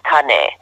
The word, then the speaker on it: Kane: I remember making sort of a Hawaii connection with him when he told me his name is actually pronounced Kane, which means man in Hawaii.